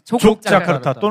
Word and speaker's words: 족자카르타: 1시간 가셔야 이제 있는 곳인데 자카르타가 아니라 족자카르타 또는 네. 욕냐카르타가 라고도 발음합니다.